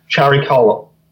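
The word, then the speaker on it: Chariklo: This is an asteroid called Charikola.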